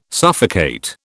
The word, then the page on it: suffocate: British English